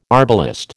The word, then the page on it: arbalest: American English